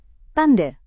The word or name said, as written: tanda